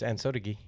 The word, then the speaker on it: Ansotegui: It's Ansotequi.